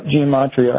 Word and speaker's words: gematria: Gematria was the word? – Yep, gematria. Mm-hmm. I think that actually is specific to Hebrew. And NRN, so there's a consonant N in the Hebrew numerology, gematria, which is 600.